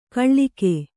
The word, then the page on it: ಕಾಳಗ: kāḷaga